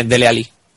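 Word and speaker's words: Dele Alli: Sí, bueno, se, se, bueno, sí, se dice Dele Ali. O sea, como si fuera una L solo.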